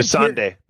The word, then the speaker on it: Missandei: So, you got to mention the strategy here, which is to take all the non-fighters, Gilly, Varys, Missandiel. I don't know if I'm pronouncing it. – Even Missandei. Missandei.